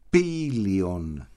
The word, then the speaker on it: Πήλιον: Πήλιον, επίτηδες τονίζω το πι, γιατί είναι πι Παύλα Ήλιον, 9 θραύσματα, όπως μετέφρασες πολύ ωραία, στην αιωνιότητα.